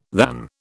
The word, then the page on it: than: British English